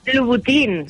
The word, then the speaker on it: Louboutin: Otra cuenta, Louboutin, ¿cómo se dice esto? – Louboutin, sí. Claro, es Christian Louboutin, y bueno, pues la marca lo tiene claro.